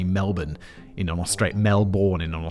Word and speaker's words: Melbourne: sitting around 13 miles southeast from Melbourne city centre.